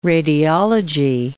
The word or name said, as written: radiology